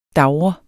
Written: DAVRE

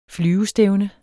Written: flyvestævne

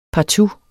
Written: partout

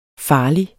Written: farlig

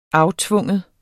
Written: aftvinge

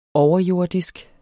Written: overjordisk

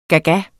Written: gaga

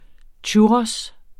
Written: churros

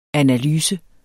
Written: analyse

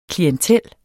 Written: klientel

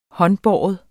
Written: håndbåren

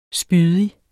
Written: spydig

